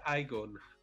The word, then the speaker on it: Iagon: With us today, we have the COO of Aegon. Did I say that right? – That's right. Well, we pronounce it Aegon. I-A-G-O-N.